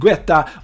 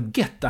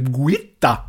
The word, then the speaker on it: Guetta: Det var inget Guetta brukade ägna sig åt, eller Guetta heter han kanske, det är franska. Det var inget getta ni får stå ut med att jag ändrade mig där liksom. Någon gång måste jag uttala det rätt. Om jag inte säger typ Guitta, för det heter han ju inte.